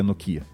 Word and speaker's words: Nokia: Nokia, Nokia. Acho que Nokia, né? É a pronúncia correta.